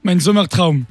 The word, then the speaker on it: Mein Sommer Traum: Tu vas me dire si je le dis bien. On vient d'écouter Mein Sommer Traum. – Mein Sommer Traum. – Tu le dis mieux quoi, forcément.